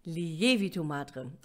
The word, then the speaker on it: Lievito madre: Es heißt Lievito madre. Also die Betonung liegt auf dem E.